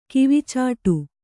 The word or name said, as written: ಕಿವಿಚಾಟು